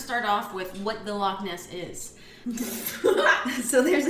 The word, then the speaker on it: Loch Ness: We're talking about Loch Ness.